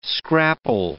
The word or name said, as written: scrapple